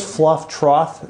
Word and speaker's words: Fluff Trough: So it's Fluff Trough? – Yeah. – How do you spell that? T-R? – Yeah, F-L-U-F-F-T-R-O-U-G-H.